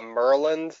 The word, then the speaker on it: Maryland: Actually, we call it Merland down here. We don't pronounce all the letters.